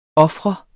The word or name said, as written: ofre